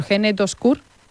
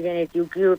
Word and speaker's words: Gene2Cure: Bueno, quiero también preguntarte, porque paralelamente continúa la investigación impulsada, a ver si lo digo bien, por Gene2Cure. – Sí, por sí, vamos, bueno, en inglés, Gene2Q, pero sí, sí, vale. Lo has dicho perfectamente.